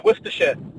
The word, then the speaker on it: Worcestershire: But, yeah, Worcestershire. – Worcestershire. – Worcestershire, yes. – So the C is silent.